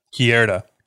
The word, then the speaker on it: Querida: How would you pronounce Q-U-E-R-I-D-A? Cuerda? – Cuerda. – Cuerda. – Mi Cuerda. – Mi Cuerda. Yes.